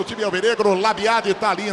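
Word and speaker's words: Labyad: Tem uns caras que falam Labiá. A pronúncia é Labiade ou Labiá, hein? – Marroquinamente falando, Marroquinamente. – Labiá. Ah, meu Deus. – Portuguesamente, Labiade. – Então tá bom. Vamos no português, né? Labiade.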